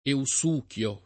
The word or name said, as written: eusuchio